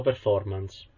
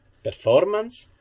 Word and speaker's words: performance: Se dico performance, si pronuncia performance. Non si pronuncia performance, perché non sta né in cielo né in terra.